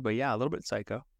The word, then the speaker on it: Cayko: Because that's very, it's spelled C-A-Y-K-O, but it's pronounced psycho. Like, are you psycho? – Yeah, a little bit.